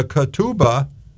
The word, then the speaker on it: ketubah: The written betrothal document is called a ketubah in the Hebrew. That's spelled K-E-T-U-B-A-H.